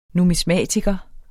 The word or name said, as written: numismatiker